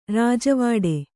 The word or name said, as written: ರಾಜವಾಡೆ